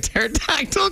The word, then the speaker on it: pterodactyl: Why can't you hear a pterodactyl go to the bathroom? Because the P is silent, you see.